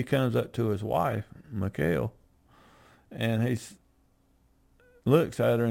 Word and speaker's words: Michal: But his wife, Michal, is a better pronunciation. I've heard both ways, but it was Saul's daughter.